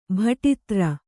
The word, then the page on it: ಬುಟೇದಾರಿ: kannada